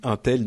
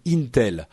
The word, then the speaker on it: Intel: on me dit Intel. Non, il faut prononcer Intel.